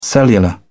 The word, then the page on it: cellular: British English